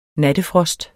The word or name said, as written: nattefrost